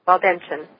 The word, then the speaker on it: Waldensian: And also, you said you're, is it Waldenson or Waldensinian? – Waldensian. – Waldensian? Mm-hmm. Oh, I was just wrong all the way around with the pronunciation.